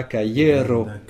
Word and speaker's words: kajero: En granda caiero. Très bien, un grand cahier. Alors un cahier, ça s'écrit caiero, donc ça s'écrit K-A-J-E-R-O, cahier.